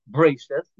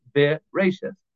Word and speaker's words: Bereshith: But there are other words like Bereshith, where we don't say Bereshith, we say Bereshith, where we pronounce the Shva.